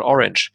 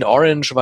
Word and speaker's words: Orange: Für mich hieß der Film immer The Cure in Orange. Ich habe es immer Englisch ausgesprochen statt Französisch. Orange ist eine Stadt in Südfrankreich, in dessen Amphitheater The Cure aufgetreten sind. Für mich war es immer in Orange,